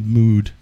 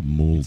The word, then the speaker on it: Mould: Bob Mood. Fucking. – And Mackay. – It's Bob Mould. – It's Ian Mackay. – It's Bob Mould. Mould.